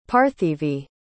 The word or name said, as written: parthivi